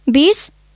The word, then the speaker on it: विष: वैमनस्य, विस्फोट, विष, व्याधा, यी शब्दमा लेख्दा व लेख्नुपर्छ, बोल्दा चाहिँ ब उच्चारण हुन्छ, होइन त काका? – हो, खास कुरा यही हो।